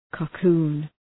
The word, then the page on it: cocoon: kə’ku:n